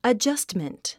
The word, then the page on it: adjustment: ədʒʌ’stmənt; アジャストメント